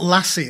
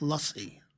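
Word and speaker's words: lassi: It's not lassie. It's not the dog. – What? – It's. Although she was made of mangoes. – Yes, I know it's spelt like the dog, but it's a sound. It's lussy. – Lussy. – Like a U.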